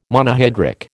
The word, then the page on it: Monohydric: American English